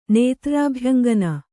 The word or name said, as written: ನೇತ್ರ್ರಾಭ್ಯಂಗನ